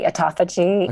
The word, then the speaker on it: autophagy: Autophagy. – Autophagy.